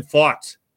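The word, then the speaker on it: Pfaadt: So I think it's a great move to have Ranger go today against Brandon Fought is how you pronounce that, of the Arizona Diamondbacks.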